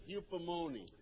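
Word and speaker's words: hupomone: It rhymes with spumoni, eupomone.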